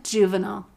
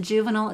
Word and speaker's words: Juvenal: But I have one just under my name, Gwen Juvenile. And I'm going to spell it because that's how I am. G-W-E-N, and then Juvenile is J-U-V-E-N-A-L.